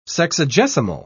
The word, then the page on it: sexagesimal: British English